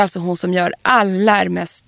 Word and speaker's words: Hermès: Hermès. – Hermès.